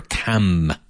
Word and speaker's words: Cam: The city's called Cambridge, but the river that flows through it is the River Cam.